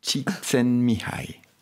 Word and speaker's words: Csikszentmihalyi: Mihail Csikszentmihalyi.